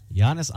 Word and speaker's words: Giannis: They're Giannis all big tall. – There you go. He even, you even said it right.